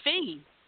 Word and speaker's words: Feige: Kevin Bay. – No, it's Feige. It's Feige. – You're talking about our Kevin, all right? – No, I'm talking about Kevin Feige, the president of Marvel. – Oh, never mind. – It's F-E-I-G-E.